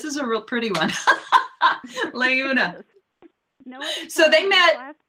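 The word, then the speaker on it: Laeuna: Layuna.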